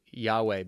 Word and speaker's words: Yahweh: And it's been pronounced Yahweh, but Jews do not use that term because it's even too holy.